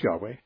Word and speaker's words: Yahweh: Yahweh, or Jehovah's Witnesses like to call him Jehovah, but the Hebrew would better be pronounced Yahweh.